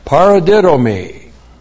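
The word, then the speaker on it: paradidomi (παραδίδωμι): And then, paradidomi. P-A-R-A-D-I-D, long O-M-I, paradidomi.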